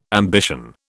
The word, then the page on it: ambition: British English